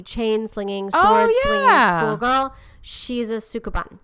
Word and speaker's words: Sukeban: Sukeban is S-U-K-E-B-A-N. – E-B-A-N. Sukeban.